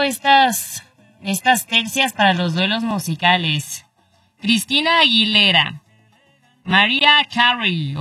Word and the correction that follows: Mariah Carey: ¿o cómo se dice, Zuli? ¿Sí, no? María Carey. – Mariah Carey.